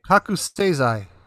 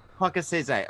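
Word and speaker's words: Kakusei-zai (覚醒剤): or quasi-seizai. Did I say that right? – Kakusei-zai. – Kakusei-zai.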